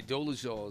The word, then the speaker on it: Dolezal: Dolezal? – And Dolezal is, I believe, how you pronounce it.